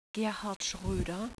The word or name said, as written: schroeder